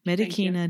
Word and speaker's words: Medicina: use the hard C because it's Latin, Medicina de Quadrupedibus,